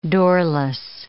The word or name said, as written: doorless